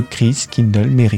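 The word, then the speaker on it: Christkindl: Et voilà comment est né, attention, la prononciation, le Christ Kindle le mhm.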